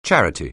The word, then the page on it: charity: anglais